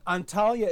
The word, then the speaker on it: Antalya: Now that you mentioned, is it pronounced Antalya? – Antalya is the city.